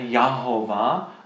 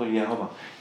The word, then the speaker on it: Jehova: tam je vlastně A-O-A, jo? Takže Jahova. A protože angličtině se to A vyslovuje jako E, takže to potom z toho vzniklo to Jehova.